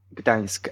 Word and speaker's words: Gdańsk: Gdańsk, at the seaside.